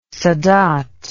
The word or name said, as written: sadat